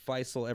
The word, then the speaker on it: Faysal: So there's a lot of the world pronounce my name Faysal instead of Faysal. – Yeah. – But it's pronounced Faysal in the Gulf and Faysal everywhere else.